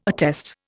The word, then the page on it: attest: Indian